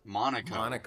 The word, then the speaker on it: Monaco: And the answer was Monaco, the country of Monaco. And I was like, there's a race there. And I was like, it rhymes with Morocco.